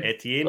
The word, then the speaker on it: Etienne: Travis Etienne, which is his French name. – Etienne, well done.